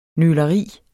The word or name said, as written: nøleri